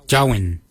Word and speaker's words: djowiny: Gard is spelled G-A-A-D. Gard. The next word is hair. For hair, we say Jowin. Jowin. Jowin is spelled D-J-O-W-I-N-Y.